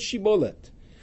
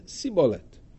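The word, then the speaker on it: shibboleth: They would say to them, tell me, pronounce the word shibolet. And they would pronounce it as sibolet. And then they couldn't pronounce Shibolet.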